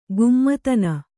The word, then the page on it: ಕಾಮಧೇನು: kāma dhēnu